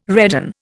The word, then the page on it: redan: Indian